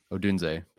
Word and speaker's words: Odunze: I do think there's a drop off between Neighbors and Odunze. I'm sorry, how do you say it again? – Odunze, pardon me.